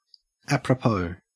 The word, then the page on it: apropos: Australian English